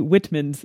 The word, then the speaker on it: Whitman's: It's the um, white man sampler. What is, is that how that's pronounced? I believe so. I think it's missing an E. Oh yes, I'm sorry. The Whitman's sampler.